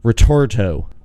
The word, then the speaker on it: Ritorto: I want to introduce and welcome to the podcast authors and writers, Marc and Kristina Ritorto.